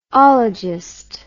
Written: ologist

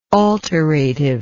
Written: alterative

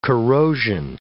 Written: corrosion

